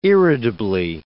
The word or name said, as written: irritably